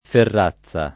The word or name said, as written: ferrazza